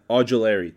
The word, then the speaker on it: Ojulari: I don't know how to say it. Ojulari. There it is.